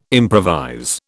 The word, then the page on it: improvise: British English